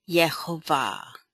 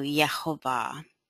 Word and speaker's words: Yehovah: And I listened to him, and he pronounces it Yehovah. Like the J would be a Y. There is no J sound in the Hebrew. So Yehovah.